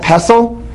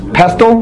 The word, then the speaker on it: pestle: Pestle? Pestle? The tea is silent.